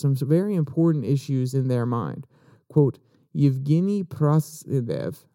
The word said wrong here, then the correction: Prigozhin: Progozen, not Zev, Zen, Progozen, according to Newsweek.